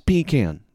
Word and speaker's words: pecan: It's pecan. Shh. I'm a little bit of a stickler on pronunciation. People try to say it's pecan or something because that's like a cute southern thing. It's just, well, that's not what, objectively, that's not how the word is pronounced.